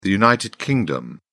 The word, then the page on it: united kingdom: British English